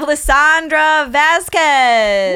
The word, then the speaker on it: Lisandra Vázquez: we have Lisandra Vázquez.